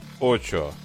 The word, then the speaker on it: AXO: Не могу не отметить, что написано, что читается от AXO как Очо.